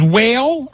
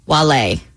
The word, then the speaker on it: Wale: This is Whale or Wale? – Wale. – Wale, not Whale.